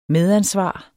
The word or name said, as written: medansvar